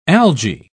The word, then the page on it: alga: British English